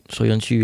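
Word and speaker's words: Söyüncü: Soyuncu, yeah.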